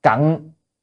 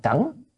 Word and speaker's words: cẩn: Cận, chữ này hôm nay chữ cận phải đọc là cẩn,